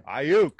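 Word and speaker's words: Aiyuk: I mean, if Brandon Ayuk, is that, how do you say his last name? – Ayuk.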